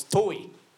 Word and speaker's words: Touhy: So the earliest sighting of the Chicagoland Gargoyle took place in 1922 by a man of the name of Walter, I guess, Touhy? It's spelled T-O-U-H-Y. I assume that's the pronunciation. – And where is Walter from? – Chicago. Chicagoland. – So it's Touhy.